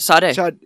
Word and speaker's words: Sade: Sade? I'm saying that wrong. – No, you're right. Sade. Sade, yeah.